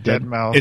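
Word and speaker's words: deadmau5: How do you say that? – Dead Mouse. – It's Dead Mouse. – Dead Mouse 5. I don't know. – It's Dead Mouse. – Okay. – But it's spelled Dead Mouse 5.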